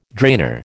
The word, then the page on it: drainer: American English